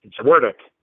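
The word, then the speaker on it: Zduriencik: Zwerdick.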